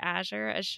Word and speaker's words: Azure: Azure, you're good.